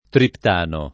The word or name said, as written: triptano